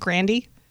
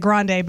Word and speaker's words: Grande: You know that it actually is pronounced Grandy? – I don't like that. That's, I don't care for that at all. – She pronounces it Grande, but her grandfather said it's Grandy.